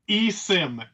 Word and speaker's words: eSIM: Am I saying it eSIN? And how would I know, if it's supported by my phone carrier? Sorry, you were saying it is. How do you say it? – Yep, eSIM, S-I-M.